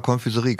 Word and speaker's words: Konfiserie: Sag mal Konfiserie, kannst du das aussprechen? – Ja, Konfiserie. – Guck mal einer an.